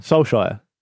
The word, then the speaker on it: Solskjaer: Solskjaer.